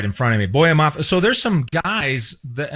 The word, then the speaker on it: Boye Mafe: How do we pronounce his name? – Oh yeah, the second round draft pick, Boye Mafe.